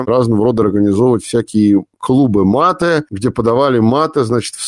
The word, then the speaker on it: мате: Вот, кстати, хороший вопрос, что популярнее, вино в Аргентине или тот напиток, о котором мы будем говорить, и это мате. Во-первых, правильно ли я произношу ударение? – Мате, да.